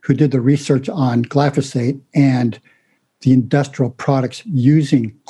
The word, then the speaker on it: glyphosate: Glyphosate, am I saying it right? – Glyphosate.